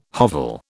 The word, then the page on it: hovel: British English